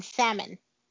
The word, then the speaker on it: salmon: It's like somebody pronouncing the L in salmon.